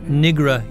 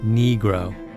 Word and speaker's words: Negro: Hollowell once paused in the middle of a trial to instruct the court on the correct pronunciation of Negro. Not Nigra, Your Honor, Negro.